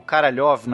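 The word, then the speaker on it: Karalev (Королёв): Então a gente vai ter aqui o Sergei Korolev ou Karalev na, na pronúncia russa.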